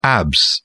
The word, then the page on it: abs: British English